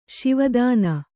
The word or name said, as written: ಶಿವಾಧೀನ